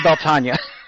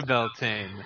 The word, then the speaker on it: Beltane: Yeah, he's pronouncing it Beltane, and I'm pronouncing it Beltania. This is simply a stylistic thing. It's spelled Beltane.